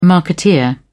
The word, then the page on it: marketeer: British English